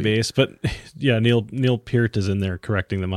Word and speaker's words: Peart: Peart. What was his last name again? – Peart. – P-E-A-R-T.